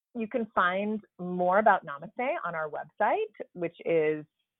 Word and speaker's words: Namaste: Oh man, it's pronounced Namaste. That sounds way cooler than the way that I was pronouncing it earlier, Judy.